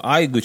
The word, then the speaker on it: Aygo: А, Айго через Эй даже пишется. – Да. О боже мой. По-японски. Айго.